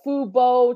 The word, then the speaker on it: Fubo: It's Fubo TV.